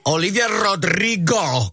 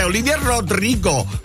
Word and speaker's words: Rodrigo: Olivia Rodrigo con Drop Dead. Si pronuncia così perché tu lo sai che quello è un cognome latino, se deve pronunciare correttamente. Olivia Rodrigo.